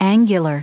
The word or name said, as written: angular